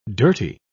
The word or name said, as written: dirty